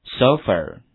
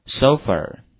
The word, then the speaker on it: sofa: 跟我读一下 shopping, 跟我读一下这个短语 go shopping, next word, 下一单词是我们说的沙发，跟我读一下 sofa, 好，这个 o 的发音是 o, 双元音 o,a 的发音是 e, 所以这个词读音是 sofa, 沙发。